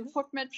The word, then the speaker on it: FODMAP: Ich habe geübt, also FODMAP heißt das Ganze. Ähm, sehr kryptisch, vielleicht kannst du uns erstmal aufklären, was man überhaupt unter dieser merkwürdigen Abkürzung versteht. Ja, wenn es jetzt schon mal richtig ausgesprochen ist, kann ja schon gar nichts mehr schiefgehen.